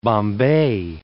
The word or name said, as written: Bombay